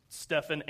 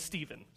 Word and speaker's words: Stephen: And it's both pronounced Stephen and Stephen. So, bear with me if you're like, hey, that was actually Stephen. I'm like, well, you know what? Everybody has no, tomato, tomato. I'm just kidding.